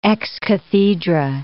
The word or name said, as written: ex cathedra